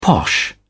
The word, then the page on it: posh: British English